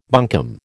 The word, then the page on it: bunkum: American English